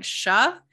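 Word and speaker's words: Shya: It was like Sha. The Y is silent.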